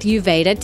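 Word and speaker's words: YOUVEDA: Now, I've teamed up with Uveda to give you, the epic MA tribe, 35% off your first order.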